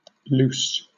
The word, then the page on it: luce: British English